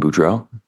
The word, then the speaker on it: Boudreaux: My name is Brandon Boudreau. B-R-A-N-D-O-N. Boudreau is B-O-U-D-R-E-A-U-X.